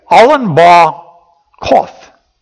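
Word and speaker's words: Allon Bacuth: Alan Ba Koth is how it's really pronounced, which means the oak of weeping.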